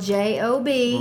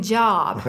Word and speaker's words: Job: and the instructor said that it looks like job, but it actually is pronounced Job.